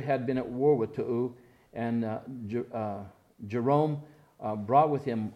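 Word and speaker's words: Tou: And it says, since when it looks like toy I, but it's actually pronounced to'u. It sounds kind of funny.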